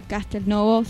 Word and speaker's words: Castelnuovo: Castelnuovo se escribe y Castelnuovo se lee.